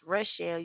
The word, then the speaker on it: Rochelle: I'm like, you can pronounce Michelle. It's Rochelle. You're just taking the M and replacing the R.